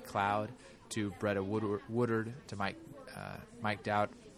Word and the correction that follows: Dougherty: I always say his name wrong. Mike Dougherty. I'm pretty sure I nailed that one.